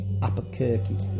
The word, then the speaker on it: Albuquerque: Isn't exactly what happens during a big DMT experience, in that no one felt themselves flying over Apakuke, that's where it's Apakuke, or visiting their relatives' home in Toledo. Albuquerque, sorry, it's Albuquerque pronounced, isn't it? A L B U Q U E R Q U E, Apokirky.